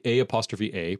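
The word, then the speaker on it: A-A (ʻaʻā): There's basically A'A, pronounced A-A.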